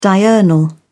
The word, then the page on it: diurnal: British English